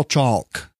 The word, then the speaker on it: chalk: First of all, chalk. – Chalk, He pronounces the L in chalk.